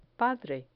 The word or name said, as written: padre